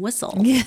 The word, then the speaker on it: whistle: I could have had a computer and the teacher could have taught her why the T is silent and whistle.